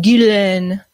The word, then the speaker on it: Ghislaine: All right, I want to switch gears to Ghislaine Maxwell. That's how you say it. What a pretentious name. Ghislaine Maxwell, age 59, locked up in a Brooklyn jail right now.